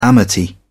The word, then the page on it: amity: British English